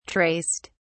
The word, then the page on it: traced: British English